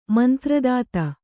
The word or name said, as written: ಮಂತ್ರದಾತ